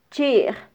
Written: chij

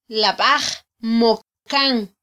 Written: labaj

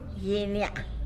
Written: gyiny ya'a

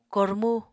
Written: kormu

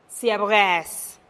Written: siab geez

